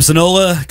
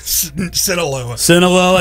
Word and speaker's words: Sinaloa: Sinaloa cartel co-founder. – Sinaloa.